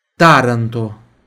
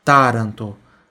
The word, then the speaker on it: Taranto: Foggia, Taranto, Taranto, também é o tá aqui, ó, que o começo que é forte, ó, Taranto, Taranto,